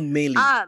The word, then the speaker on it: Meili: It's called Meili. Meili? Or I don't know how you pronounce it, but it's M-E-I-L-I.